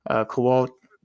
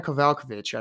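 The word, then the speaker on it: Kowalkiewicz: She, you know, like I said, lost to Emily Ducati in her last time out, but beat Carolina Kovalkovic. I always fuck it up. Carolina Kovalkovic, I believe, is how you say it.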